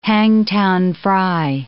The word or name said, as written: Hangtown fry